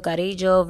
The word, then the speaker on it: Carrillo: You just butchered his name, didn't you? – No, I didn't. I am a Hispanic. I know how to pronounce a name. – I sound like you put a D in there. – Carrillo. – That's how you supposed to say it? – In Spanish, yes.